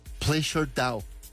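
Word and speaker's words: PleasrDAO: Es como unos, pues como unas iniciales, se pronuncia Pleasure Dow, en una operación valorada en varios millones de dólares.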